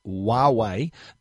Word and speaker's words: Huawei: Our next review is from Huawei. That's how you pronounce it. It starts with a H, but it's pronounced Huawei.